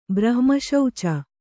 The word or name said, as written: ಬ್ರಹ್ಮಶೌಚ